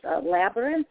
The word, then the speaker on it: labyrinth: I would say, I think the word you're trying to pronounce was labyrinth, is how... – Labyrinth. Okay. – Yeah. – So simple. So, labyrinth, just like it's written.